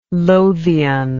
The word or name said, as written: lothian